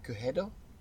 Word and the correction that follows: Quijudo: Quijedo. Quijedo. – Yeah, don't. – Quijedo. – Soft J. – Quijudo. Quijudo. – Quijudo. – Quijudo. There it is.